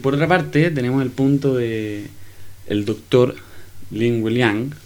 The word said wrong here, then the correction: Li Wenliang: no sé cómo se pronuncia, pero Li Wenliang, perdón, Li Wenliang, así.